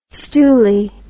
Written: stoolie

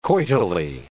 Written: coitally

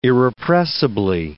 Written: irrepressibly

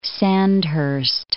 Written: Sandhurst

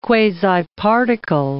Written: quasiparticle

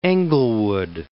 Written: Englewood

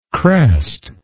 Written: crest

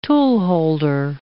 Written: toolholder

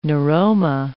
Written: neuroma

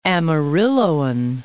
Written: Amarilloan

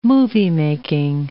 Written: moviemaking

